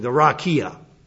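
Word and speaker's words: rakia (רקיע): The verb rakia, you want to pronounce the Hebrew word? Okay, well, it's the rakia.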